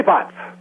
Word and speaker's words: pazzo: "Tu e pazzo" means you're crazy, And Pazzo is spelled P-A-Z-Z-O, like pizza, Pazzo.